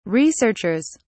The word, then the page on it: researchers: British English